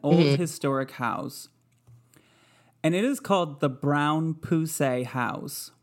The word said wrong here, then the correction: Brown-Pusey: I actually called them because I wanted to make sure I was saying it right. And it's Pusey. It's the Brown Pusey House. But it's Brown hyphen P-U-S-E-Y.